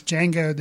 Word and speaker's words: Django: For some reason, even though they bring it out in the movie that it is Django, the D is silent, I constantly, when talking about it with my wife, I'll go like, Django.